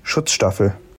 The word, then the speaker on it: Schutzstaffel: Was the Nazi Party's original paramilitary wing, and SS, or Schutzstaffel.